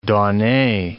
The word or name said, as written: donnee